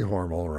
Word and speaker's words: Hormel: And my brother, in a stopover at the Cincinnati train station, made a little medallion for my father that said, J.C. Hormel rhymes with normal.